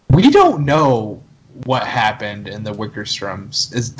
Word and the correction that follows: Wickersham: Wickersham story.